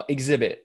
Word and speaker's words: Xzibit: Uh, Xzibit.